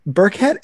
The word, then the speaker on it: Burkett: And I'm like, Burkett is not that weird of a last name. It's two syllables. It's not hard to pronounce.